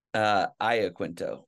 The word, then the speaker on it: Iaquinto: Now, since I always forget, let me get your name right again. Iaquinto? – Aya Quinto. – Iaquinto. All right. But Yeah. – is it an Italian name? – It is Italian, yeah.